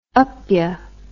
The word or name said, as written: upya